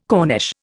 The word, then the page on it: corniche: Indian